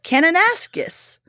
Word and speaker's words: Kananaskis: Kananaskis.